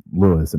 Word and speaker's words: Louis: My name is Louis, and it's spelled L-O-U-I-S,